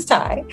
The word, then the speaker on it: Tai: So my name is Ty, but I also pride myself on being a tycoon. So I spell it T-A-I, because that's how you spell my first name, C-O-O-N.